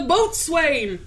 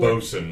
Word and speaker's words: boatswain: It's spelled boatswain. – It's pronounced bosun. – I'm going to keep saying boatswain. – It's a bosun. – So, why? Why are we just getting rid of letters? – That's how it's pronounced. It's just the way it is. – Have you met the English language? – Bosun? But, like, there's no... – Bosun. – Bosun? We're getting rid of the T and the A, huh? – And the W. It's usually pronounced B-O-S-U-N is how it's pronounced.